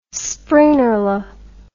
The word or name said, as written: springerle